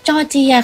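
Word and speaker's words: Georgia: จอร์เจียคำนี้นะคะจะสะกดด้วยตัว G E O R G I A อีกครั้งนะคะ G E O R G I A อ่านว่าจอร์เจียค่ะ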